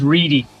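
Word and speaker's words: Reidy: His name is Shane, and it's R-E-I-D-Y. I fear I've always pronounced it wrong. – The surname is Reedy.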